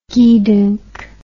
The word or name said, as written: gedunk